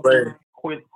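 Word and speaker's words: Coi: Koi Lorraine. Shift the radio. Koi, Koi, fish. Koi Lorraine.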